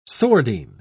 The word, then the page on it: sordine: British English